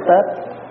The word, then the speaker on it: Tết: Một năm có 8 Tiết, mùa xuân, mùa thu, mùa hạ đều là Tiết hết. Mà mình nói riết nó trại ra thành chữ Tết, chứ đúng ra mình đọc là Tiết.